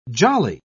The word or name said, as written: jolly